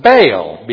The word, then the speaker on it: Baal: We would pronounce it Baal, B-A-A-L.